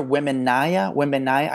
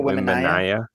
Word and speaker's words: Wembanyama: Victor Wembanyama. I just met the guy. Victor Wembanyama.